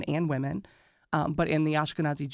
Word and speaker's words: Ashkenazi: And then I also read something about being of Eastern European descent, the Ashkenazi Jews. – Yes. – I think I said that right. – Yes, Ashkenazi Jewish individuals, which about 90% of Jewish individuals in America have that ancestry.